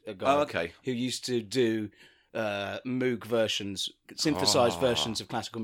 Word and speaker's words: Moog: The actual pronunciation of the guy's name is Moog.